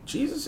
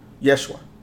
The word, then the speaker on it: Yeshua: And Jesus is not how it's pronounced. I don't know if people know that or people really studied that. There's no letter J in the Hebrew language. Ja does not exist. Yeshua, that's the name.